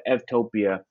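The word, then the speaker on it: eutopia: So we just put the EU on topia, and EU in Greek is pronounced, the V is pronounced as a V. So instead of U, it's Ev. And so Evtopia is a good place.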